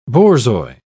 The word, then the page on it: borzoi: British English